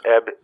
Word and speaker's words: Ebn: Hi, I'm Eb Emus. – Eb? – Eb. – Eb Emus. – Yes, Eb, E-B-N. The N is silent.